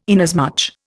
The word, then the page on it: inasmuch: Indian